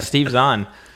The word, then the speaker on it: Steve Zahn: And we were wondering how you got Steve, I'm going to butcher his last name, Steve Zahn. – No, that's it. Mm-hmm. You there was no butchery happening there. Yeah, Steve Zahn.